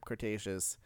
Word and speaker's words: Cretaceous: Cretaceous. Yes. Sorry, I had to make sure. – You've done this. – Devin was giving me shit for saying it wrong.